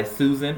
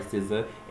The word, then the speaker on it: SZA: The first one's going to be 20-something by Susan, or SZA. I'm sorry, SZA. S-Z-A. As I used to call it for like a hot minute before I figured out how to actually pronounce it.